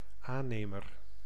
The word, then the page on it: entrepreneur: /ɑ̃.tʁə.pʁə.nœʁ/